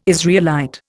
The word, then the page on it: Israelite: Indian